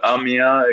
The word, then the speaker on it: Amiens: The Amans are facing the Saint-Quentin. – Yeah, I can't pronounce that. – So, Amiens against Saint-Ouen.